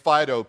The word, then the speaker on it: Phydaux: My buddy had a hunting dog down there named Fido, P-H-Y-D-A-U-X.